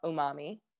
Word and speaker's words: umami: I didn't know about this until you told me about it, but the topic is, I'm going to say it wrong. Umami? – Umami. – Right? Umami? Umami. Okay. Yeah.